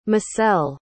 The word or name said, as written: macel